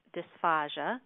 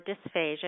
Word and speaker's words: dysphagia: So the last area that we're going to talk about today in my part of the webinar is dysphagia, or dysphagia. It's pronounced either way, and I wanted to make you familiar with that term because that's the medical term for a swallowing problem.